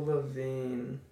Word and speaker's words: Lavigne: Avril Lavigne. The G is silent.